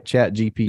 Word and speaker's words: ChatGPT: Did I say it right? ChatGPT. – You did. GPT.